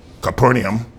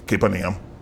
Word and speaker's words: Capernaum: And when Jesus entered into Capernaum, actually that's the way it's pronounced. A lot of people say Capernaum. It's Capernaum. Doesn't matter, really.